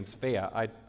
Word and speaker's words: Svea: S-V-E-A is her name, Svea. I looked it up. That's how you pronounce it, Svea.